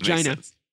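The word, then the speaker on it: Geena: She just goes by Gina. Gina, actually. – Gina. – It's pronounced Gina.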